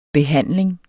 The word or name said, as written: behandling